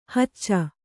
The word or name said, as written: ಹುಚ್ಚ